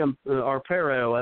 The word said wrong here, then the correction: Arpaio: Um, Sheriff Arpaio, definitely. Arpaio, thank you for pronouncing that for me.